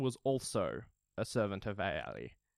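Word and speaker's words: Aulë: I believe he's the servant of Aeol. The Valar in charge of crafting and smith work. – I think it's pronounced Ale.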